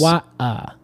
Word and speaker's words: koaia: Yeah, it's Acacia, and it's spelled K-O-A-I-A. – Yes. – Kawaii.